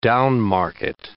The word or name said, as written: down-market